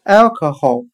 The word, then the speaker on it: alcohol: 我们用谐音法来记忆这个单词 alcohol，它的发音很像汉语的爱可喉，爱喜爱的爱，o 表示语气词。